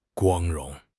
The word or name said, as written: 光荣